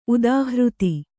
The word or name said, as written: ಉದಾಹೃತ